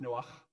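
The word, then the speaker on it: Noah (נח): it's Noah. It's got a little like phlegmy sound at the end, so in the Bible, if you're reading in Hebrew, it's Noah, which is just more fun.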